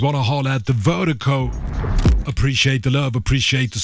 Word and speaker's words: Virdiko: And Vertigo, it's spelled V-I-R-D-I-K-O. That's V-I-R-D-I-K-O, pronounced Vertigo.